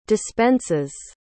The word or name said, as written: dispenses